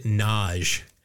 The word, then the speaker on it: Nagy: There was a woman in the league whose last name was Nagy, but she pronounced it Naj. And it just screwed me up because I've known Charles Nagy.